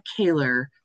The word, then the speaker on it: Kohler: So nobody knows quite how to pronounce it right off the bat, but my name's Andrea Kaler, spelled Andrea Kohler.